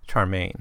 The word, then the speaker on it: Charmian: Now, London begins his account with a discussion with his then wife, or I guess his first wife, Charmaine. I think that's how it's pronounced. C-H-A-R-M-I-A-N. It's not a name I've come across in other contexts.